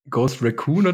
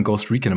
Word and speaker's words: Ghost Recon: Ihr habt doch am Anfang Ghost Recon immer falsch ausgesprochen. Habt ihr nicht immer irgendwie Ghost Raccoon gesagt? – Ghost Raccoon, genau.